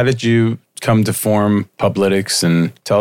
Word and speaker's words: Publitics: To make and how do you pronounce, is it Publitics? How do you pronounce that? – I think I had that one. – That's fine. Yeah, no, you pretty much nailed it.